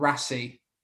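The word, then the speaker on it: Raasay: And the other one was on Rassi. Is that, I think that's how you pronounce it.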